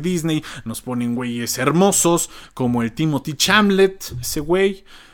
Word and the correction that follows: Chalamet: O sea, no, a Timothy Chamblet no, no sé si se dice así, o oh, Chalamet, el Timothy, el Timmy.